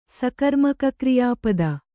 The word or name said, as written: ಸಕರ್ಮಕಕ್ರಿಯಾಪದ